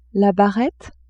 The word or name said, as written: barrette